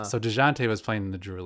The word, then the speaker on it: Dejounte: No, I guess maybe he, like, bumped into DeJean, maybe? – Oh, DeJounte. Is it DeJounte Murray? – Yeah. – Okay, yeah. – DeJounte, is that how you say it? – DeJounte, yeah.